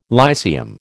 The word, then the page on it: Lyceum: American English